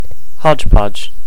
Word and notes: hodgepodge: American English

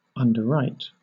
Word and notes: underwrite: British English

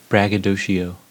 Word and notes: braggadocio: American English

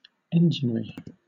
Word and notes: enginery: British English

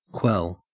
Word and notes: quell: Australian English

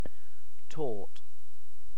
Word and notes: torte: British English

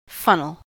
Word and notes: funnel: American English

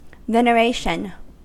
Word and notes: veneration: American English

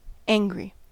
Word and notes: angry: American English

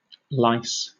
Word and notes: lice: British English

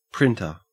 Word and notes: printer: Australian English

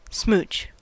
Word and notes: smooch: American English

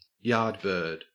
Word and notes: yardbird: Australian English

